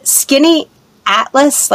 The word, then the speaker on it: Skaneateles: Is it Skaneateles, like skinny, like thin Atlas? – So that's how it's pronounced.